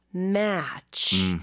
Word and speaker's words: match: 근데 A 발음을 좀 더 길게 하셔야 돼요. 아하. 그래서 match보다는 match.